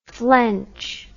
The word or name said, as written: flench